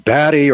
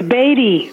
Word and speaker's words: Badie: I think the first question to address, it's an extremely important question, Susan and Margie, is it Batty or Beatty? That's the question I've got for B-A-D-I-E. – Is it? It's Beatty.